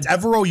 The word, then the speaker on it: Ejiro Evero: Ejiro. – Okay, so I just butchered that. – Evero, you got right, the last name. The first name Evero was brought over from Denver.